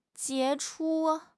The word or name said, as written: 杰出